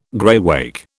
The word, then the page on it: graywacke: British English